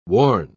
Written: warn

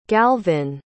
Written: galvin

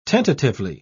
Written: tentatively

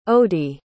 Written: odie

